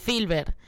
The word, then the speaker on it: Filbert: ¿Lo digo bien? Filbert. – Sí, Filbert. Pues